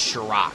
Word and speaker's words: Shirok: It's pronounced Shirok. – Shirok. – Not looks as like, bad as it looks.